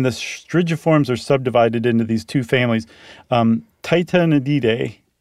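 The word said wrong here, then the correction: Tytonidae: I added an extra syllable. – Mm-hmm. – Titanidae.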